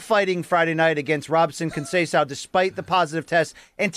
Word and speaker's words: Conceição: He goes, pronounce his name for me, Conceição.